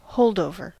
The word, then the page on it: holdover: American English